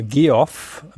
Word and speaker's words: Geoff: Aber jedenfalls der amerikanische Vorname Geoff wird einfach ausgesprochen wie Jeff, weil es einfach eine Form von Jeff ist.